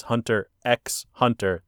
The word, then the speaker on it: Hunter x Hunter: And the worst part is you see it written out as Hunter x Hunter. The X is silent.